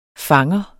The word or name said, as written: fanger